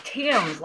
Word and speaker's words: Thames: Thames, like the Thames River.